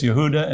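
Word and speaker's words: Yehuda (יהודה): In Hebrew, Judah is Yehuda and Jew is Yehudi.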